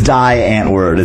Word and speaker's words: Die Antwoord: I don't try and say it like I'm from Africa, because I'm not from Africa. I'm a northeastern coal person. – Yeah. – So I'm just like, you know what? It's Die Antwoord. It's what it's spelled like. And, you know, that's fine. I'm okay with that.